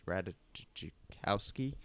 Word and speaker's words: Ratajkowski: No, I'm just kidding. It's Ratajkowski. – Emily Ratajkowski. – Anyway, I don't know how to pronounce it. – The J is silent.